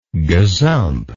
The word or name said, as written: gazump